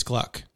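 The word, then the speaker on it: Glueck: How do you pronounce that? – I think it's Gluck.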